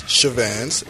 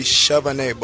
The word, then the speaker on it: Chavannes: Well, there's two ways to pronounce it. There's okay. The English way, which is Chavannes, but the origin is French. So really, it's supposed to be Chavannes, but I'm not trying to sound that bougie.